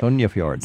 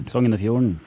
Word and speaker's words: Sognefjord: Actually, I just took that same area a year ago and did the Sognefjord. I'm not sure how you say it. – Sognefjord. – Sognefjord. – Yes.